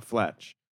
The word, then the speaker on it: Fletch: I might stick to See How They Run or Confess Flesh. Fletch? How do you say it? Confess Fletch? – Confess, comma, Fletch. – It's not Flesh, no. – Confess, it wouldn't be Flesh.